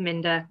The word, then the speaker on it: Minder: it is minder rhymes with tinder i